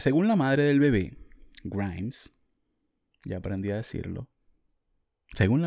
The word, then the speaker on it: Grimes: Se dice Grimes.